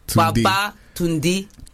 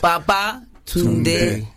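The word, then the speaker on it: Babatunde: Baba Tunde. – Tunde. So say it like you're saying come back or returned. – Baba Tunde. – Yeah. It's like a song.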